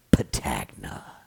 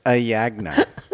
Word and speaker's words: Patagna: Uh, Jansen emailed me this week, and he noted that the P in Patagna is silent. he said it's actually pronounced Jansen Ayagna.